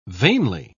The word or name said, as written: vainly